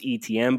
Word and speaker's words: Etienne: That's Etienne. That'd be the one. – He's the one that I'm like, I keep leaning back towards Etienne, but I know Taylor pretty intimately watching the Big Ten so much this year, and he's really good.